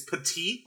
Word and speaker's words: Petit: I can't say petite. – It. – It's petite, like the word petite. – See, I always thought it was petit, like petit Frere.